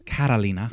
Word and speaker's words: Catalina: So it's written Carolina, but it's pronounced Catalina.